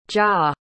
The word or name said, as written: jia